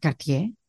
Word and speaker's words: Cartier: Cartier, eh, Cartier, para pronunciarlo mejor.